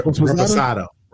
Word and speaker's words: Reposado: Resposado. Am I saying that right? – That's close enough. – Reposado.